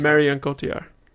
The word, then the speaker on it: Marion Cotillard: Marion Cotillard.